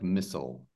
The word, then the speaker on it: mistletoe: So it's the T is silent. So it's like mistletoe. Like that. That's the phonetic.